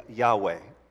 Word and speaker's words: Yahweh: Um, you, you, may have also heard it as uh, pronounced as Yahweh.